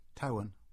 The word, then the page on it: Towan: English